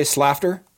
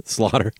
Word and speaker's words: Slaughter: How do you say Slafter? – Slaughter.